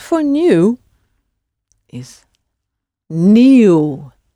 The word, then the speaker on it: nieuw: Spelled N-I-E, so that's that E sound, but then added to that is a U. And of course, it, like in new, it ends in the W. So it's N-I-E, U-W. New.